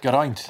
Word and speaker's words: Geraint: Geraint.